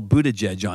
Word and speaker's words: Buttigieg: I'm going all Buttigieg on you.